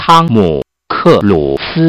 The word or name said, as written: tom cuise